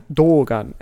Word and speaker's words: Dogan: Dogan. And they wrote it wrong on the back because on the subtitles, Dougan, meaning yeah. It's a long O.